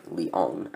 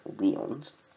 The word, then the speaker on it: Lyon: I also want to thank listener Tim for catching my mispronunciation of Lyon as Lyons,